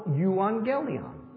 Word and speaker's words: euangelion: It comes from the Greek word for gospel, euangelion. So it's E-U. So if you take that U and turn it into a V, then you can pronounce evangelism a little easier than euangelion. So it's the evangel, or it's the euangel, and it simply means good news.